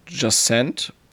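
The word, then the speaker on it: Jusant: Das nächste ist dann Just Jacent, wird es, glaube ich, ausgesprochen. Das ist von ähm, Not, das neue Spiel, ne? – Äh, richtig.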